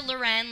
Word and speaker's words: Loren: Gabrielle, and that's spelled like the girl's name, GABRIELLE, not Gabrielle, _ Loren, like Sophia Loren.